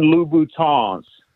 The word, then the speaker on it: Louboutin: And then most importantly, So the women that listen to the show don't start tagging you on YouTube, they are Christian Louboutins. You got to pronounce it with the French.